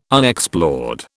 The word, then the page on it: Unexplored: British English